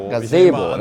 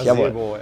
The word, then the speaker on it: Gazebo: Gazebo, natürlich. Gazebo, Gazebo.